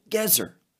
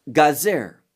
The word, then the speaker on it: Gazer: Now, many times it's called Gezer, but here it's Gazer, and the emphasis, Gazer, is on the first syllable.